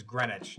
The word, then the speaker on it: Greenwich: Greenwich is spelled here. It's spelled Greenwich. – It's also a Greenwich. – Place in England. – Yeah, Greenwich. Cool, Greenwich. Yeah. Spelled, and it's Greenwich.